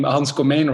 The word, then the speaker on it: Komijn: Well, I was already happy you said the name Hans Komijn right, because usually English speakers don't get that right.